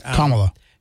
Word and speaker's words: Kamala: And I want to make sure I pronounce her name right after this morning. Kamala Harris. – Kamala.